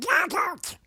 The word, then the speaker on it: Donald: Donald. – What? – Donald. – How do you spell it? – D-O-N-A-L-D.